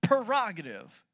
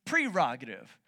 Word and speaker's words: prerogative: I pronounce it prerogative. I was saying it wrong. You're saying it wrong. The way you say it is actually prerogative. That's your prerogative.